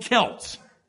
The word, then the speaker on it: Celts: Before the time of our Lord Jesus Christ, the people that stretched from Ireland to Galatia, where Paul preached, were called Celts. C-E-L-T-S. You can pronounce it Celts. No one cares. Both are correct. Both are equally correct.